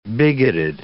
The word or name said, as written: bigoted